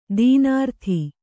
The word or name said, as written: ದೀನಾರ್ಥಿ